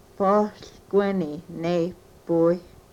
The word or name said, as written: pohlkweni nepuy